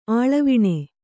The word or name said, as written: ಆಳವಿಣೆ